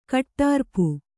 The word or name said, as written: ಕಟ್ಟಾರ್ಪು